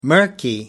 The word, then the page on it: murky: American English